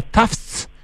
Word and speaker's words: Tufts: Catalina Justiniano, arquitecta de la Universidad Católica y magíster en políticas y planificación urbana y medioambiental de la Tufts University.